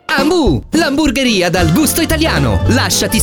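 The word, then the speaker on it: Hambu: Ambu è a Varese, Lecco, Meda e Vimercate. Ambu si scrive con l'H. – Ambu, mi piaci tu.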